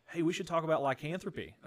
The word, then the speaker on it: lycanthropy: And that is the topic of and correct me if I'm saying this wrong, but lycanthropy. That's right. Is that right? That's right.